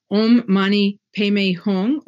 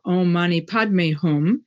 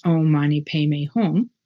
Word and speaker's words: Om Mani Padme Hum: Om Mani Padme Hum, or Om Mani Padme Hum. That's the Sanskrit pronunciation, and the Tibetan one is Om Mani Padme Hum.